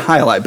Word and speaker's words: Jai Alai: The Jai Alai from Cigar Hi-Li? City. – Yeah, it's pronounced Hi-Li. Hi-Li, but yes.